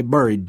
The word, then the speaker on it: buried: They buried Dee. He said the verb the old way so it rhymes with scurried.